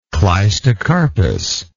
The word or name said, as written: cleistocarpous